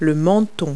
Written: chin

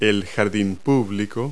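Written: public garden

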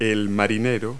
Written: sailor

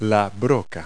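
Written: drill bit

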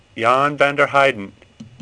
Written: Heyden

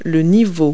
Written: level

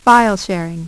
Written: File Sharing

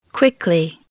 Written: quickly

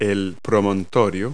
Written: headland

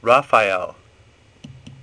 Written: Raphael

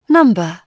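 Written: number

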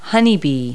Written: honeybee

